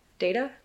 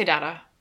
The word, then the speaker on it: data: Data? How do you say it? – I say data. – Data. – But data is also correct.